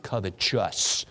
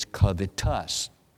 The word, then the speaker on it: covetous: By the way, you don't pronounce it covetous. There's no E after the S as in righteous. It's covetous. You say, boy, you're real smart.